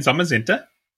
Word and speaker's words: Zamazenta: Zacian and Zamazenta. Did I say that right? Yeah. – Zacian and Zamazenta.